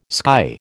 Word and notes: sky: American English

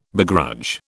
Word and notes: begrudge: British English

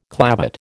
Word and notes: clavate: American English

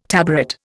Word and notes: tabaret: Indian

